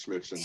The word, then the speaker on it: Smithson: Yes, it is Smithson.